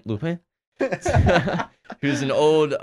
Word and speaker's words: Lupin: It's called Lupin, which is spelled L-U-P-I-N, so you can just say Lupin.